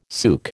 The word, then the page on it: souk: American English